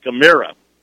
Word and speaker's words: Camara: He spells his last name like Camara, but pronounces it Camara.